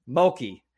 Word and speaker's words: Mulkey: Mulkey. – I look at it and I'm like, is that like murky, but with an L? – Okay.